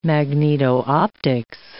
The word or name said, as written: magneto-optics